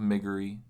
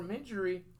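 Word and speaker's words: Miggery: Book the Third is Gore, the Tale of Migory Sal. – Or Midgery. – Or Midgery. – Pronounce it how you like.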